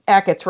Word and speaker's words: Achatz: And so like all the articles would say, you know, Grant Achatz, and then it would say Achatz rhymes with packets or something.